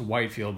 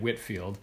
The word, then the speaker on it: Whitefield: George Whitefield, it looks like it's pronounced Whitefield, but it, you actually say it Whitfield, was the face of this movement.